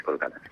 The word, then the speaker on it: Kolkata: Bueno, sí, es Calcuta, pero en indio es Kolkata.